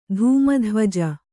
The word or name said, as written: ಧೂಮಧ್ವಜ